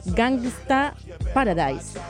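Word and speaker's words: Gangsta's Paradise: Justamente Coolio se dio a conocer con este éxito, que sería como Gangsta Paradise.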